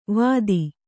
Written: ವತಿ

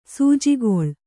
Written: ಸಜ್ಜುಗೊಳ್

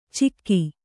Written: ಚಿಕ್ಕಿ

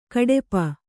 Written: ಘಾಟುಪ್ಪು

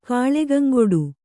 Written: ಕಲ್ಕಂಗೂಡು

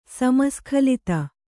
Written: ಸಮಸ್ಖಲಿತ

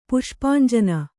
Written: ಪುಷ್ಪಾಂಜನ

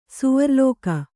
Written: ಸ್ವರ್ಲೋಕ

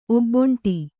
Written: ಒಬ್ಬಂಟಿ

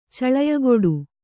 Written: ಚಳಯಗೊಡು

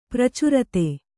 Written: ಪ್ರಚಾರತೆ

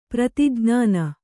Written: ಪ್ರತಿಜ್ಞಾನ

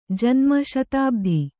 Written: ಜನ್ಮ ಶತಾಬ್ಧಿ

